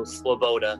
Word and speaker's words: Svoboda: You can get away pronouncing the V like a W or a U, so Svoboda.